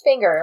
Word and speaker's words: Finger: Just Finger, like a finger on your hand.